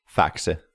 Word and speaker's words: Faxe: Faxe.